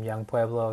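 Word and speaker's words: Yung Pueblo: I write under the name Young Pueblo.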